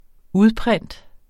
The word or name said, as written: udprint